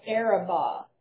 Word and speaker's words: Arabah: as far as the Sea of Arah, would be restored. – Yes. So it's Lebo Hamath and the Sea of Arabah.